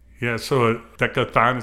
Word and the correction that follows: decacorn: is that how you pronounce it? – Decacorn. – Decacorn. – Yes.